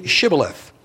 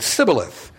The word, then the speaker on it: Shibboleth: When he said, "No," they said to him, "Well then, say Shibboleth." And he said, "Sibboleth," for he could not pronounce it right.